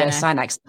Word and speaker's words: Astyanax: Yes, there we go, Astyanax. I add an extra syllable, which is not supposed to be that.